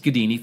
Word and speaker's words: Gaddini: My name is David Gedini. It is not a common name, so you will find me quickly, all right? Last name is spelled G-A-D-D-I-N-I, pronounced Gedini, think Houdini, but guh, all right?